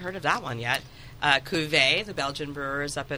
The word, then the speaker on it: Cuvée: Although he makes us say Cuvée. – Oh, nuts. – Yeah, because it's Belgian. Yeah, he's Flemish. Cuvée. That's how they say it.